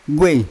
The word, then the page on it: ugei: Yawa